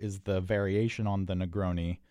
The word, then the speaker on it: Negroni: The cocktail is, it's spelled N-E-G-R-O-N-I, first word. Second word, S-B-A-G-L-I-A-T-O.